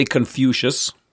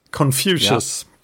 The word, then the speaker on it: Confucius: I say Confucius. – Confucius, yeah.